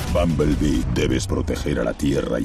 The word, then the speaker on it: Bumblebee: Vamos con la superproducción estadounidense de la semana, a ver si soy capaz de pronunciarlo bien, porque debería ser algo así como Bumblebee. – Muy bien, Bumblebee.